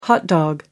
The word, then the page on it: hot dog: American English